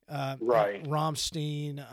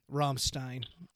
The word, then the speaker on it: Rammstein: big legendary bands like Metallica or Rammstein. I said that incorrectly on purpose. – Send us your email. – Rammstein.